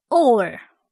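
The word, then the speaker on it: or (אור): 今天我们要看的一个字是光，光亮的光，读音呢是 or， or。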